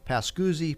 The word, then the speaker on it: Pascuzzi: And here in the studio this morning, we have Harry Pascuzzi and Susan Pascuzzi of Pascuzzi, Pascuzzi... – and Stoker. – And Stoker Law Firm, specializing in real estate and estate planning. Is that the right way to say it? – Yes. And civil litigation is the Stoker part of it.